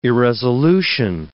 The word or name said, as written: irresolution